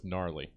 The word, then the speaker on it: gnarly: Oh, yeah, and always, guys, always remember, don't go hard G with gnarly.